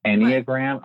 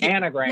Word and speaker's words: Enneagram: I just realized I've been saying it wrong for years. It's Enneagram. I'm like, anagram.